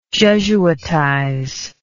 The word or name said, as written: jesuitize